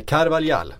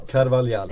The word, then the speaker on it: Carvalhal: Carvalhal ska det uttalas. Det vi säger ju ofta fel. Vi blandar ihop portugisiska och spanska uttal hela tiden i den här podden.